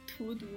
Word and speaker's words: 荼毒: 啊，是查毒吗？我怎么记得好像是另一个另另一个。啊，我来查一下那个那个那那个词怎么念来着？你继续讲，我找查到给你给你说。 – 荼毒吗？ – 对，是荼毒。